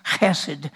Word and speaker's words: hesed: Loving kindness is a Hebrew word, hesed. It's pronounced hesed, and it's H-E-S-E-D, and it means a loyal love.